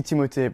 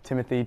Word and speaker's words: Timothée: It's supposed to be Timothée, but that always just seemed like too obnoxious requirement to put on people. So Timothy, Tim, Timmy.